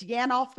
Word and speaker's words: Yanof: That is as close as it needs to be. It's Yanof, but I answer to a lot of things.